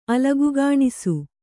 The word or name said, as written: ಅಲಗುಗಾಣಿಸು